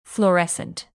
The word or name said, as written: fluorescent